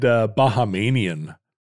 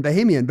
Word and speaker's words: Bahamian: I said Bahamanian for a long time. – You added a syllable? – That was a Roderickism. – Well, that's because that's what Panama does. It should be Panamian, like right. Canadian and Bahamian, but it's not.